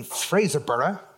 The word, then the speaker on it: Fraserburgh: So that brings us to the third show, May the 23rd, in Fraserburgh, which I'm totally paranoid about pronouncing.